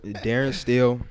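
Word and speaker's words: Darin Steele: Look, Darren Steele. D-A-R-I-N. Don't spell my name wrong. S-T-E-E-L-E.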